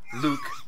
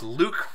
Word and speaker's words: Luc: It's French. Luke. – Whatever, I don't give a fuck. It's Luck for me. – No, it's not. It's Luke for everybody.